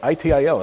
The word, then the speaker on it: ITIL: You made it through that ITIL.